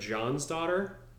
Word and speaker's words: Jonsdotter: It's spelled J-O-N-S-D-O-T-T-E-R. So, but you know, it's like it's like Johnson. – Yeah. – It's spelled Johnson, but it's definitely John's son.